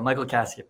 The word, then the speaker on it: Michael Casia: Yeah, Michael Casia.